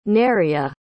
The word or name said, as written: neria